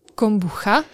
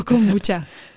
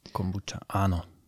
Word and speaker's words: kombuča: My reagujeme na obojí teda, ale primárně se snažíme o tu korektnou výslovnost a to je právě kombuča. Mm-hmm. Není to tím, že bychom chceli být jako nějaký americký, anglický. Je to spíš o tom, že to vlastně reflektuje ty původné slova z Ázie, které popisují ten nápoj sám. A tam se to teda vyslovuje takto.